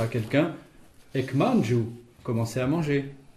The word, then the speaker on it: ek: Ek, ça s'écrit E-K, et ce préfixe indique le début d'une action ou sa soudaineté.